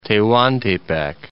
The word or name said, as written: Tehuantepec, Isthmus of